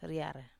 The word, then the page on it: riar: wamesa